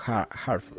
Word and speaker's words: HA:TFELT: Lo hace bajo el seudónimo Harf. Es digamos como que una, una abreviación de Heart Felt desde la pronunciación del inglés británico, que suena como Hot, suena desde el, desde el inglés británico.